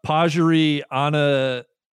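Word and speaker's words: Pajaree Anannarukarn: How about Pajari Ananarukarn? – Perfect. – Would that qualify? – That's exactly how she says it.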